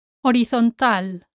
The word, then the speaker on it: horizontal: Horizontal, horizontal. Here you need to remember that the H is silent, horizontal, and that the stress falls on the A, horizontal.